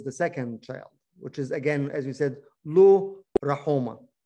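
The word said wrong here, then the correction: Lo-Ruhamah: Lo Rohama is the actual pronunciation.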